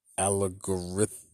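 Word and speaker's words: Allegorithmic: Algorithmic is how I should have pronounced that.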